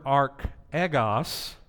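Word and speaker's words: archegos (ἀρχηγός): Because this word archegos, think about it. That's how you pronounce it in Greek, is actually the word arch ego.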